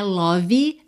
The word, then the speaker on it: love: Vamos aproveitar que é dia dos namorados, que nós estamos falando de amor, para caprichar na pronúncia dessa palavra, que não é love, não é love, é love, love. L-O-V-E. Bom, o E não é pronunciado. E o O não é um O caprichado, gordinho, redondinho.